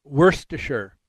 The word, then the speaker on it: Worcestershire: but they said that the last part of it, the S-H-I-R-E, Shire, in the English pronunciation, the way the British do, is it's just the word sure. – Sure. – Like in New Hampshire. – Okay. – So Worcestershire. So Worcestershire sauce.